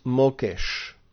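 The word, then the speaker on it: mokesh (מוקש): And that word in Hebrew, translated snare, is mokesh.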